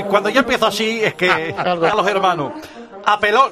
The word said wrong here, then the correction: apelo: No, no lleva tilde. Es apelo. Apelo, no apelo.